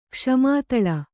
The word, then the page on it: ಕ್ಷಮಾತಳ: kṣamā taḷa; Kannada